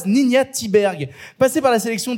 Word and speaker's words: Ninja: Tu l'as vu, qu'est-ce que tu en as pensé alors, premier truc, le vrai, la vraie prononciation du nom de la réalisatrice, c'est Ninja.